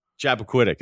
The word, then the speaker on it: Chappaquiddick: In Chappaquiddick, how do you say that? – Chappaquiddick. That was good.